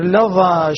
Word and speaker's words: لظى: لاحظ البيت اللي بعده: ظاهر لظى شواظ كظم ظلما، أغل الظلام ظفر انتظر ظما. نفس الشيء، كلمات ذكرها ابن الجزري كلها تنطق بالظاء وليس بالضاد، بالظاء وليس بالضاد.